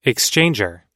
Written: exchanger

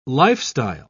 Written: lifestyle

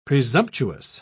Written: presumptuous

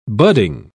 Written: budding

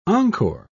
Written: encore

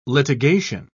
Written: litigation

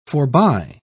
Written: forby

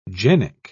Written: genic

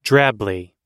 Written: drably

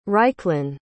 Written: ryclen

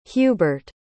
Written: hubert

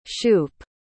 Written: shoop

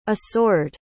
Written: isoard

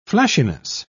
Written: flashiness

